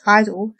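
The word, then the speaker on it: I-DLE: G-Idol, or just Idol, as their name is supposedly pronounced, is a group I've followed casually for a very long time.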